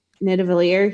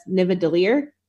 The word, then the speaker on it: Nidavellir: Which is, honestly, it's funny because Thor, the first scene that he says it, he mixes up the V and the D. So instead of saying Nidavellir, he says Nivadilir.